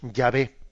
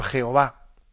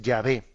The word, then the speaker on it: Yahvé: Pero dichas en nuestro alfabeto son la Y, H, W, H, y nosotros las pronunciamos Yahvé. Del siglo XVI al XIX, en muchas Biblias se transcribió por Jehová, por Jehová, del siglo XVI al XIX. Luego los estudios filológicos tuvieron más conciencia, avanzaron más y llegaron a entender que la pronunciación más correcta, más que Jehová, es Yahvé. Yahvé.